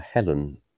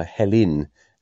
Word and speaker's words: Helen: One thing I wanted to say was we've got a daughter called Helen, and perhaps it's because we're down south, but we call her Helen as it's spelt. But in the Archers, it always grates on me when people, especially Pat and Tony, call her Helen, as if it's her name spelled H-E-L-I-N.